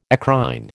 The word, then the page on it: eccrine: American English